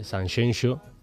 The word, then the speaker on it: Sanxenxo: A ver, Javois, Sanxenxo, si lo digo mal, dímelo, porque un andaluz diciendo Sanxenxo siempre es complicado. – Todo en orden.